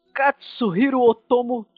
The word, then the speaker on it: Katsuhiro Otomo: O filme chega pra gente com a direção do Katsuhiro Otomo, tá certo? – Exatamente. Olha, tá ótima pronúncia.